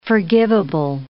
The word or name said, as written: forgivable